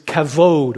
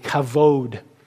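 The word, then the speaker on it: kavod (כבוד): And the Old Testament word is a word, the Hebrew word is kavod, or kabod, as it's spelled with a B, but you say V, kavod.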